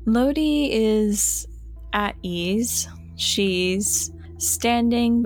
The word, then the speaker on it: Lodee: I believe right towards the end of our last encounter, Lodi had made an appearance. Did I say that right? – Yeah, you did. – Awesome.